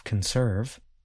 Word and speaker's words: conserve: In the verb conserve, I stress the second part of the word. Listen again. Conserve.